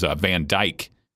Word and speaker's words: Van Dyke: That was Van Dyke, if I'm not mistaken, from the U. – Yep. Yeah. – Yeah, and that is how you say his last name.